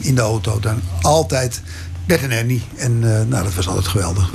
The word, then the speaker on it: Bert en Ernie: En dat wel als Bert en Ernie, want zo moet ik het uitspreken, mochten uitbrengen.